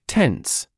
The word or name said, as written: tense